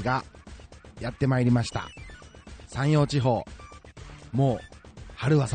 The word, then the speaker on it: 山陽: 近畿圏はですね、まあ大体、午後の最高気温が 10 度前後を記録する日が続いているんですけれども、はい、山に太陽の陽と書いて山陽地方と読む広島県にお住まいのこの方、地元の気候はいかがなもんでしょうか。